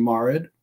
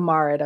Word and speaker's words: Marid: It's Shafit and Marid. – Oh, Marid. Okay.